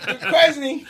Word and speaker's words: Duquesne: pronounced it Duquesne and not Duquesne. – That's right. Duquesne.